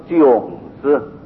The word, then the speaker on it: 炯: 所以两位要读作炯字。